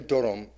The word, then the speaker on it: dorom: World Vibes Dorom Fest. Because Dorom is how you say it there.